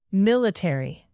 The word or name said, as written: military